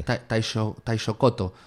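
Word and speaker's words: taishōgoto: que es el taishokoko. Vamos a ver si lo digo bien. ¿Es el taishokoko, se dice? – Taishokoto. – Taishokoto. – Sí.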